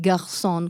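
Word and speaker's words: Garzón: Vive a Brooklyn e le sue origini, però, l'hai detto bene quel garçon con questa R un po' così,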